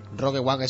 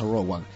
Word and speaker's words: Rogue One: Rogue One es Rogue One. – Ah, o sea, Rogue, lo dije mal, ¿no? – Se pronuncia Rogue, en realidad, Rogue One, o sea, Escuadrón Rogue 1, o sea, Rogue One.